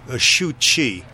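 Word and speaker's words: Suu Kyi: In different ways, as when I talk about the artist Giacometti alongside the democratic imprisoned leader in Burma, Xu Chi. That's how her name is pronounced, S-U-U-K-W-I.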